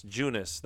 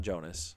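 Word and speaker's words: Joonas: Junis, not Jonas.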